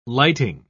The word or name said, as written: lighting